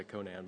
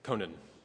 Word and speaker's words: Conan: I always want to say Conan, but that's like Schwarzenegger's character. But Conan, I think is the way you pronounce it, on a late night show.